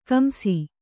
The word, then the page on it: ಕಂಸ: kamsa